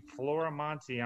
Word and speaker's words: Floramonte: I always spell it because nobody can seem to spell it right, even though it's spelled exactly the way it sounds. Floriamonte, Floramonte.